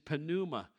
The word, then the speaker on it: pneuma: And you go, well, when you have a P and an N together, you don't pronounce the P. It's like pneumatic drill or pneumatic hammer. Yeah, that's fine. You're not Greek. In the Greek language, they always pronounce the P. Why? Because it's there. If it's there, you should pronounce it. The English language is kind of weird that way. We do things the way we want to, but in the Greek, it is pneuma.